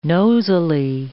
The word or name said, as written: nosily